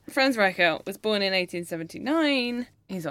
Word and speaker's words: Reichelt: I think it's Reichelt. The third Reich is spelt like that.